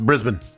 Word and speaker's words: Brisbane: It's spelled Brisbane, but it's Brisbane.